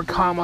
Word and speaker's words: Kamala: Pray for the Vice President-elect, Kamala Harris, I believe that's how you say her name. Or comma, I've heard her say it both ways.